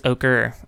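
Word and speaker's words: Ocre: Yeah, I'm pretty sure it's Okra. It's O-C-R-E. – Oh, okay. – All the names are in Spanish, so. But I think Okra's a name.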